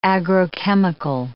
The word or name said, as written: agrochemical